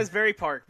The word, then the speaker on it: Asbury Park: Asbury Park, baby.